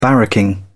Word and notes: barracking: British English

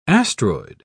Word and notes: astroid: British English

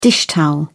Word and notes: dishtowel: British English

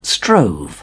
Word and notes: strove: British English

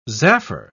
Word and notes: zaffre: British English

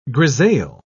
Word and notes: grisaille: British English

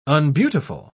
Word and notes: unbeautiful: British English

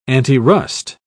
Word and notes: antirust: British English

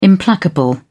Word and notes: implacable: British English

